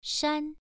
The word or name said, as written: shan1